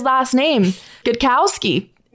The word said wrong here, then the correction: Gostkowski: is it Cal- or is it Gostkowski? – Gostkowski. – He's got it.